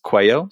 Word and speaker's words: Coelho: My name is spelled Adam, A-D-A-M. Last name is Coelho, C-O-E-L-H-O.